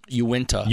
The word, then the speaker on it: Uinta: Uinta.